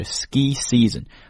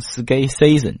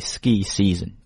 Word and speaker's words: season: 注意这个词 ski 是长音的 e, 然后这个 season 也是长音的 e, 所以是 ski season, 不要读成 ski season 啊。很多中国同学这个 e 经常发到 a 上面，变 season。 Not right. We're going to say ski season.